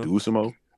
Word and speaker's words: Dosunmu: uh How do you pronounce his name? Do uh oh Desumu Dosunmu. Dosunmu. – Yeah, Dosunmu. Yeah, Dosunmu.